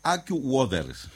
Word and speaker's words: AccuWeather: ¿AccuWeather se dice así? ¿Usted que sabe inglés? – AccuWeather. – Ah, bueno, muy bien.